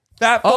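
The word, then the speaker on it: Phat Farm: P-H-A-T. Fat Farm. Oh. And it had that P with like the sound.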